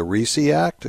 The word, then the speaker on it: RISE Act: I guess it's pronounced the RISE Act. Is that right? – Right.